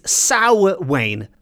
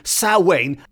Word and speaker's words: Samhain: which is spelt Samhain, and if I'm pronouncing it correctly, it's Samhain, but it's spelled S-A-M-H-A-I-N.